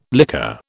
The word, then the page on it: liquor: British English